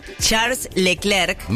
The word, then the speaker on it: Charles Leclerc: Charles Leclerc.